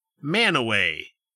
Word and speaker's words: Mantua: Well, just take, for example, the town of Mantua, which in this state is pronounced Manaway. – Yep, that makes sense. – M-A-N-T-U-A. – Mantua.